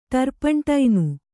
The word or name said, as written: ಟರ್ಪಂಟೈನು